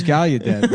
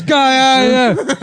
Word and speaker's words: Gallaudet: Like, you know that's not how it's pronounced? – Gallaudet. – No, it's this whole time I thought it was Gallaudet, but apparently it's Gallaudet.